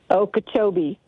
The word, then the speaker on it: Okeechobee: Okeechobee.